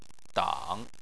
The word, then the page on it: dang: 3